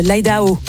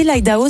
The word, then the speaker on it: Idaho: Tu m'as quand même dit l'Idaho, ça n'existe pas. – Mais l'Idaho. – C'est plutôt l'Idaho. – Ah, mais ah, excusez-moi, tu l'as bien prononcé, je t'en prie. – Je n'ai pas eu ta raison. – À l'américaine, parce que comme vous le savez, je suis de Californie, moi.